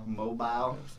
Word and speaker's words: Mobile: Mobile.